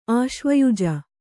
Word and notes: ಆಶ್ವಯುಜ: Kannada

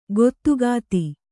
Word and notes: ಗದುಗದ: kannada

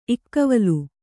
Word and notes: ಇಕ್ಕವಲು: kannada

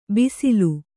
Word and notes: ಬಿಸಿಲು: bisilu

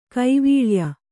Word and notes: ಕೈವಲ್ಯ: Kannada

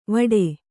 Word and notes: ವಾಟಿ: kannada

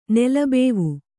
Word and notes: ನೆಲಬಾವಿ: nela bāvi

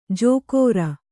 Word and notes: ಜೋಕೋರ: jōkōra

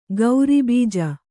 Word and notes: ಗೌರಿಬೀಜ: gauri bīja

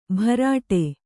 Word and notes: ಬರಡೆ: kannada

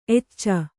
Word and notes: ಎಚೆ: kannada

